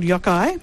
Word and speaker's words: Yokai: The footy show is called Yokai, and it was phonetically pronounced for me from Moddy, which is lucky because I'm not good with names, but it's a Noongar word for victory.